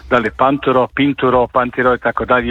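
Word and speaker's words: Pinturault: Dobro, za Panturo ću vam reći, njega smo doslovce pitali kako se zove i čovjek nam je rekao da se zove Panturo.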